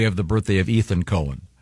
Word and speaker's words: Cohen: This that's confusing because no, not when it's spelled C-O-H-E-N. – It's definitely Cohen.